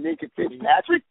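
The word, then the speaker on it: Minkah Fitzpatrick: Ever since they made that trade with the Dolphins, picking up a cat named Minka Fitzpatrick, they've been on a ride. – There you go. So you said it right there.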